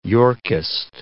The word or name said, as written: Yorkist